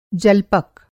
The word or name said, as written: जल्पक